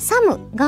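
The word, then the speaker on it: thumb: サムが、最後に B がついてるんですけど、まずその B は発音しないというのと、だから、さ、ン部とかにはならないというのと、あとは最初に th がついてるから、サムではなく、これさ、さですよ、皆さん。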